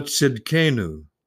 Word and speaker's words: Tsidkenu: It's Jehovah Tsidkenu, and you spell that T-S-I-D-K-E-N-U.